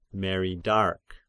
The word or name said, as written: meridarch